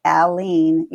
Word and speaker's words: Aline: So you take the man's name, Al, and then you take the word lean, and that's it. Aline. You say the L twice.